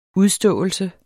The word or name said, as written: udståelse